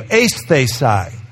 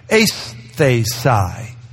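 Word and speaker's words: Aesthesi (αἴσθησις): Aesthesi. There you go. Aesthesi. That's a long A sound and a diphthong at the beginning. Aesthesi.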